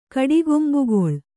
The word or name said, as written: ಕಡಿಗೊಂಬುಗೊಳ್